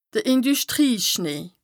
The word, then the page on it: de Indüschtrieschnee: Bas-Rhin; Strasbourg